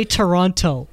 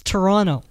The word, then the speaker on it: Toronto: Don't say Toronto. It's Toronto. There's no T.